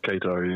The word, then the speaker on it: Qatar: Qatar. Am I saying it right? – Qatar, yeah.